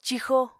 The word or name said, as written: cixo